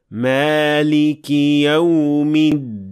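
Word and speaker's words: Maliki Yawmiddin (مالك يوم الدين): Now here I didn't vibrate the del enough, so it should be Maliki Yawmiddin.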